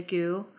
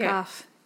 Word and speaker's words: Gough: So 3 months after this minor assault trial, they commit their first known murder, as a couple, which was of 19-year-old, Linda Gu. Gu might not be the way that you pronounce it. It's G-O-U-G-H. – Gough. – Goof?